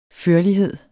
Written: førlighed

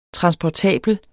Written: transportabel

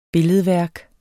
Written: billedværk

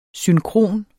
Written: synkron

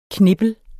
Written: knippel

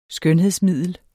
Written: skønhedsmiddel

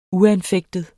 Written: uanfægtet

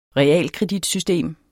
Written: realkreditsystem

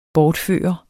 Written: bortfører

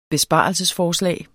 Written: besparelsesforslag

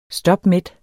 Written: stopmæt

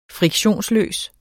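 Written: friktionsløs

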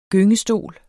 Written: GYNGESTOL

